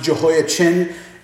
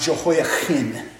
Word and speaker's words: Jehoiachin: The king there, by the way, that got taken to Babylon is King Jehoiakim, C-H-I-N, sometimes pronounced Jehoiachin, It's probably actually pronounced Jehoiachin, because that would be the Hebrew guttural there.